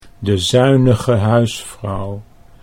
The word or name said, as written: de zuinige huisvrouw